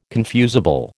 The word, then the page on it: confusable: American English